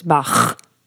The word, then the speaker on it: Bach: Or today, I was driving, and I know the proper way to pronounce it's Bach.